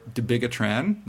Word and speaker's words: Dabigatran: These are Dabigatran, the trade name is Pradax, Rivaroxiban, trade name is Xarelto, and Abixaban, and the trade name is Eliquis. Did I say that right? – Eloquently.